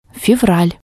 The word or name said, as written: fevral